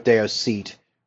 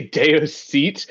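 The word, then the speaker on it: Daos Ceit: Did you say Deo's seat? Wow, that's very different from how it sounds in the audiobook. I wrote it down as, like, Dower Set. – It's spelled D-A-O-S. Yeah, it's spelled D-A-O-S space C-I-E, or C-E-I-T.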